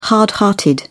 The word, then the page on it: hard hearted: British English